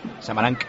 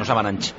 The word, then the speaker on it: Samaranch: CH se pronuncia K, Samaranch, no Samaranch.